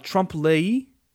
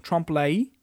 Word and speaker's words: trompe l'œil: That additionally, there are things called, and I had to look up how to pronounce this, trompe l'œil, which I assume it's a French thing, trompe l'œil.